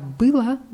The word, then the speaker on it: было: Wird als A ausgesprochen, weil es unbetont ist, aber mit O am Ende. Wenn ich sage, вчера было воскресенье, sage ich, gestern war Sonntag.